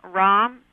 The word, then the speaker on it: Raum: The first one was Tom Rahm, His last name is spelled R-A-U-M.